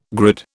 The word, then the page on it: grid: British English